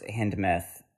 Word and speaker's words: Hindemith: Originally, the score, the score's Hindemith, and am I saying that right? – Yeah.